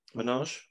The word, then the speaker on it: Manoj: Minaj. M-A-N-O-J. – Minaj. – Minaj.